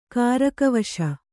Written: ಕಾರಕವಶ

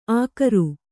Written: ಅಗರು

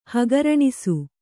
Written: ಹಗರಣೆ

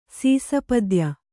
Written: ಸೀಸಪದ್ಯ

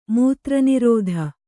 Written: ಮೂತ್ರನಿರೋಧ

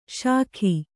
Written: ಶಾಖಿ

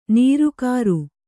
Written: ನಿರಾಕಾರ

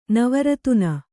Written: ನಿವರ್ತನ

